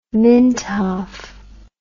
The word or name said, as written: mintoff